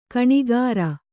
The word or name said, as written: ಗುಣಕಾರ